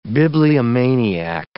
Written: bibliomaniac

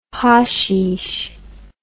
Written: hasheesh

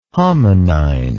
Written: hominine

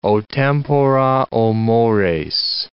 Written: o tempora! o mores!

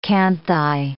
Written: canthi

